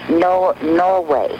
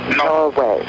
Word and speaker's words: Norway: Norway. Norway. – How do you spell that? – M like Nancy, O-R-W-A-Y.